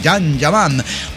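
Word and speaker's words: Can Yaman: Con el can, que no es el perro, sino el Jan Yaman, ¿no? Ah, aprendimos el nombre. Se pronuncia Jan Yaman.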